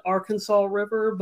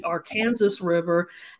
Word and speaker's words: Arkansas River: You know, out of the other maybe 49 states, you can call it Arkansas River, but in Kansas, it's the Arkansas River, and they will let you know.